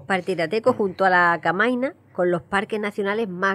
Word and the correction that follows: Canaima: Canaima es donde hemos dicho que es donde está el, el Salto del Ángel y Tapirateco está muy cerquita.